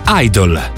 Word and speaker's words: Idol: Idol, idolo. Sostantivo che in inglese si pronuncia esattamente come idol, lavativo.